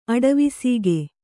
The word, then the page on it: ಅಡವಿಸೀಗೆ: Kannada